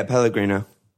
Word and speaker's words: Pellegrino: Yeah, Pellegrino.